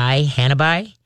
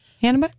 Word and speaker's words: hanabi: It's Hanabi. – Hanabi.